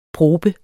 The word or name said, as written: probe